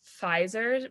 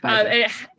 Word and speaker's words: Pfizer: Is so the vaccine that's been approved in Canada is the Pfizer vaccine? Yes. PZ, whatever, however you pronounce it. Pfizer. Pfizer, yeah.